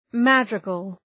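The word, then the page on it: madrigal: ˈmædrıgəl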